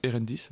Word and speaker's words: Erendis: Erendis, ouais. ouais. T'as dit enfin comme si c'était AE, mais ça s'écrit. – Non, c'est E, ouais.